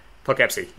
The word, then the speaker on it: Poughkeepsie: Anyway, it's the Poughkeepsie Tapes, which I am very proud of because I think I pronounced that right. So that's how you pronounce it? – Poughkeepsie, yes.